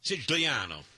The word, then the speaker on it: Sicilia: Siciliano.